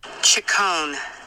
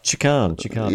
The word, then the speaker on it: Ciccone: Ciccone. – Ciccone. Yeah, two C's are pronounced K in Italian. – Yeah, Ciccone.